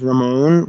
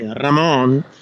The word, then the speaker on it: Ramon: Usually I say my name is Ramon when I speak in English. Spanish people or Latin American people say Ramon.